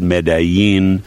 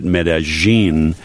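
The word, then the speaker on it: Medellín: Some people pronounce it Medellin. Colombians pronounce it Medellin.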